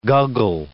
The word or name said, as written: guggle